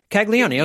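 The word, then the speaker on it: Caglianone: And then outfielder Jack Caglione of the Royals. Caglione. Okay. The first N is silent then.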